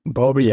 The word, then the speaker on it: Bowlby: Bowlby, ja, genau.